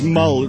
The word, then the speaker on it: Mull: The name is Mull. It rhymes with tall, and there the similarity ends.